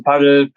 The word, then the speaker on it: Padel: Ähm, es wird in Spanien Padel ausgesprochen. Aber in Deutschland kannst du Paddel, Paddel, kannst du alles im Grunde sagen.